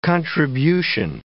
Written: contribution

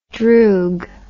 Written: droog

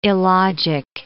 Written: illogic

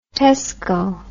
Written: tesco

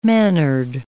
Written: mannered

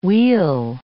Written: we'll

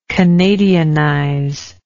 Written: canadianization

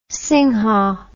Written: singha

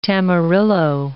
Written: tamarillo

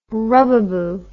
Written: rubbaboo

